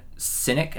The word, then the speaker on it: Sinek: Simon Sinek. Am I saying that right? S-I-N-K. S-I-N-E-K is his name.